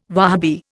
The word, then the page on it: Wahabi: Indian